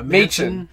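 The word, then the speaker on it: Machin: His last name is almost Machine, I just realized. – Yeah. – Machen. – Yeah, but it's spelled like Machine without the E, which is funny because, like, you could argue he kind of is like a machine.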